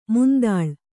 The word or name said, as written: ಮಂತುಳ್ಳಿ